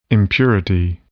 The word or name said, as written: impurity